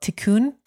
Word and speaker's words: tikkun: And you talk about, again, and I'm correct my pronunciation if it's wrong, this Kabbalistic tool is called tikkun. – That's right.